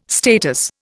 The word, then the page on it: status: Indian